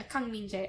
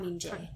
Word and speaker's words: Kang Min-jae: I think, like, min, M-I-N, is pronounced, like, mean. Like, okay. – So, if that's... – Mean Jay. – Mean Jay.